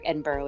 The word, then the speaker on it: Edinburgh: So it is spelled Berg like Pittsburgh, Edinburgh is, but they pronounce it Edinburgh, right?